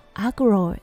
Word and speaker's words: aggro: はい、そのワードは agro です。はい、これ AGGRO と書いて agro と読みます。はい、これアグレッシブがオーストラリア風にアレンジされてアグロと発音します